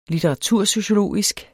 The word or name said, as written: litteratursociologisk